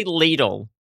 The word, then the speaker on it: Lidl: Is that how it's pronounced? – It sure I've never is. Been. If you go to the self-checkout, thanks for shopping at Lidl. – That doesn't sound right. – Lidl. It's literally Lidl.